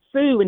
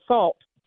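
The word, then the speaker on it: Sault: That's S-A-U-L-T, which is pronounced Sioux instead of salt.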